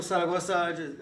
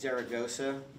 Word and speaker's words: Zaragoza: My name is Michael Saragossa. I don't like saying it too much because they think like I know Spanish when I say it like that. So it's Zaragoza. That's what it is.